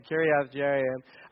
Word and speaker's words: Kiriath Jearim: And it ended up in this little town called Kiriath Jearim. That's the official pronunciation. So if you want to sound like an expert, that's what you say. Kiriath Jearim.